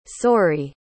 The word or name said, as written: soary